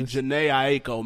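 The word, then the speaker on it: Jhené Aiko: First of all, I thought that was Jahina. Ain't the Gina KO? Jahina KO. – The name. That's my baby. Janae Aiko, man. You got to say it right.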